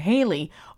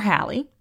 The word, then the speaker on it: Halley: It was named after an astronomer by the name of Edward Halley, or Halley, you can pronounce it either way, who discovered the comet's orbit.